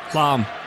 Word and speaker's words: Lahm: Lahm.